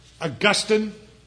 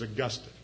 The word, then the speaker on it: Augustine: Remember Augustine? Everybody calls him Augustine, but as Jim Edwards says, that's in Florida. The right way to pronounce it is Augustine.